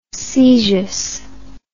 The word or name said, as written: caesious